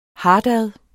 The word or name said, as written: hartad